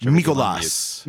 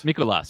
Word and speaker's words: Mikolas: I want to, aside from those two, I want to talk about Miles Mikolas. I don't know how to say his last name, because I watch Mikolas. – Mikolas. – Miles very Mikolas. Greek. Very Greek.